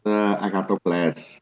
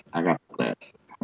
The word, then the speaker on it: Agathocles: It's Well, in Greek, it's called Agathocles. Agathocles. Well, that's the ancient Greek pronunciation.